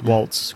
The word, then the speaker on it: Waltz: Waltz.